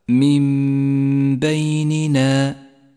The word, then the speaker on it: من بيننا: يأتي بعد ذلك حكم الإقلاب عند قوله من بيننا جاءت النون الساكنة وبعدها حرف الباء، تقلب النون الساكنة إلى ميم نقف عندها بغنة زمنها حركتان، ولذلك لونت باللون الأخضر للدلالة على موقع الغنة، بينما لونت النون الساكنة باللون الرمادي إشارة إلى عدم نطقها، تقرأ هكذا من بيننا.